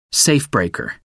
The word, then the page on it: safebreaker: American English